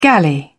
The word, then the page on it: galley: British English